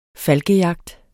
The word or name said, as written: falkejagt